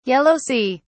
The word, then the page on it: Yellow sea: British English